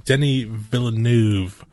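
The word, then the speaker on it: Denis Villeneuve: Well, mainly with Denny Villeneuve. I believe is the way you actually say his name, which I said it completely wrong.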